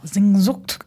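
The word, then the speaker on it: Sehnsucht: You pronounce it as Sinzo. – Sinzucht. It's the spelling is S-E-H. – Yeah. – And S-U-C-H-T, right? – Yeah.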